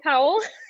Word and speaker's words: Powell: Is that how you say it, Powell? – Yep, that's right.